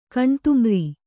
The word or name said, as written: ಕಣ್ತಿಮಿರ್